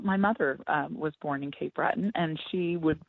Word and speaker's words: Cape Breton: and we are talking about Cape Breton. Is that how you pronounce it? – Yes, yes, Cape Breton.